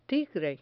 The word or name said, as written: tigre